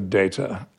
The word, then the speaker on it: Data: Should it be Data or Data? And I was the one that campaigned for Data.